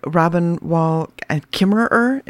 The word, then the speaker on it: Robin Wall Kimmerer: And you quote Robin Wall Kimmerer, am I saying that correctly? – Mm-hmm.